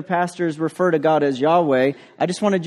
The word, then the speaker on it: Yahweh: which in the English we pronounce as Yahweh.